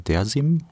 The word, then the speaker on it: Dersim: gerade in der Osttürkei gibt es auch diese Bestrebungen, Dersim, heißt es richtig ausgesprochen? Ja, da kommen wir her, ja.